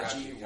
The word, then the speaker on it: Yachty: Yachty. Yachty. Come on, get that whack ass name right. – Is it Yachty? Yachty or Yachty? – It's spelled like yacht, like a fucking boat.